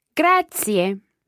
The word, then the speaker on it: grazie: Grazie. – And remember those three syllables there. They're all run together quickly, but there are three syllables. Grazie.